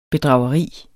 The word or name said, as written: bedrageri